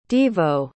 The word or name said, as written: devo